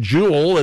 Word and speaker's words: Joule: Jewel, it is spelled oddly, J-O-U-L-E, Jewel Finance.